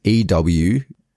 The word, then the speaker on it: Uatu: I'm going to say it's Euatu.